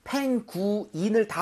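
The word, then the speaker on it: penguin: 발음할 때 펭귄이 아닙니다. 여러분들, 영어에서는 모음이 이렇게 철자가 모여 있다고 해서 뭉칠 수 있는 게 아니라고 했었죠. 그렇기 때문에 펭구인을 다 발음을 하는데 대신에 빠르고 부드럽게 하는 것뿐입니다. 그래서 펭귄 하지 마시고요. 펭귄. 구인. 요 정도의 느낌이 살짝 나게 펭귄.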